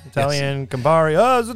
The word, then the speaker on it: Campari: They were bought by an Italian Campari group. Am I saying that right? You're Italian. – Yeah. Yeah. – Yeah, Campari.